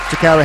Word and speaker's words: Tocarra: So without further ado, let's get into this Direct Line Artist Interview with Tocarra Hamilton.